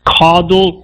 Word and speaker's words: caudal: The guy was born with a rare birth defect called I'm butchering this word, but it's called caudal regression syndrome. – Okay. Cartilage regression? – No, caudal. It's C-A-U-D-A-L. Caudal or that's how it's put. No. Caudal. Yeah.